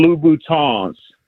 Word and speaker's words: Louboutin: And then most importantly, so the women that listen to the show don't start tagging you on YouTube, They are Christian Louboutins. You got to pronounce it with the French.